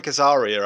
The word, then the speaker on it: Azaria: Okay, that's Hank Azaria, I believe you pronounce his surname.